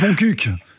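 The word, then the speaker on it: Montcuq: Ça se prononce Moncuq, en fait.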